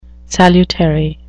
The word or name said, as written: salutary